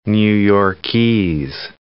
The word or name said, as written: New Yorkese